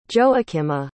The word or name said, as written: joakima